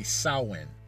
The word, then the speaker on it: Samhain: The correct way, to say what looks to be is the word Samhain is actually Samhain. It's an Irish word. And so it's not pronounced the way it looks.